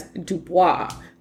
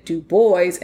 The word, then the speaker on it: Du Bois: And this is why the Du Bois family of Great Barrington, Massachusetts, pronounced it Du Bois and not Du Bois, as it is usually pronounced.